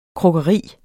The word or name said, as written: krukkeri